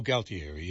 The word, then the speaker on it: Galtieri: Not Gutierrez, is it? – How would you spell that? No. G-A-L-T. – Oh, Galtieri, yeah.